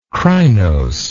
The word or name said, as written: crinose